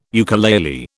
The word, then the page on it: ukulele: British English